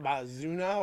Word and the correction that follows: Mazouz: Or it's Mazouz.